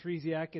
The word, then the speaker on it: Treziak: My name is Ryan Treziak. It doesn't sound the way that it's spelled, and I am one of the associate pastors at Eastridge Baptist Church in Kent, Washington.